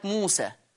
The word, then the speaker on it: موسى: احيانا بيجيني زي كلمة موسى، موسى دي بتتكتب ياء بس هي اصلا بتتنطق ايه ولا تتنطق ألف يا جماعة؟